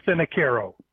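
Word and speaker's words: Finocaro: Actually, I would spell it phonetically for you because that's how most people end up pronouncing it, and it's F-I-N-O-Fino. C-A-R-O, Caro. So Lou Finicaro.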